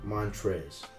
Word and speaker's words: Montrezl: Montrez. – There's no L at the end. – There is an L at the end, but it's silent.